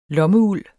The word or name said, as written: lommeuld